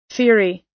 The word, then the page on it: theory: American English